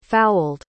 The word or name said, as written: fowled